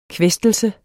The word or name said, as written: kvæstelse